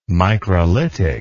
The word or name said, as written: microlitic